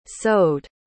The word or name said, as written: sewed